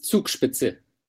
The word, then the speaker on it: Zugspitze: Yeah, the name is Zugspitze.